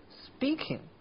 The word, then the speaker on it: speaking: 我觉得还不难 speaking，不过我有另外一个疑问，就是这个单词里明明有一个字母 p，可是发出来的音却是 speaking，为什么呢？